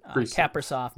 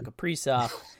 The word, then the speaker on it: Kaprizov: So I'm trying to condition myself to say Rossi, much like I had to retrain myself on Kaprizov to Kaprizov.